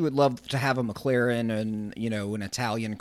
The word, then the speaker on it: McLaren: I'm going to pronounce it wrong, probably the Mechlin from? – McLaren. – McLaren is what I'm trying to say.